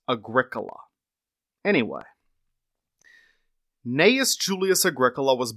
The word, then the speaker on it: Agricola: You look at this name, and it looks like it's pronounced Agricola.